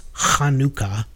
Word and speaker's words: Hanukkah: And the holiday, it's actually pronounced Hanukkah, which is always fun. It sounds like you're clearing your throat. It's there is no ha, that hard sound in the English language.